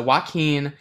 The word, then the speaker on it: Joaquin: However, I always say this name wrong, and I know it's, like, Joaquin, maybe? – Mm-hmm. – Okay. – That's good.